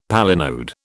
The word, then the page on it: palinode: British English